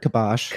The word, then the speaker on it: kibosh: So, also, isn't it kibosh? – I've heard kibosh. – Kibosh. Okay.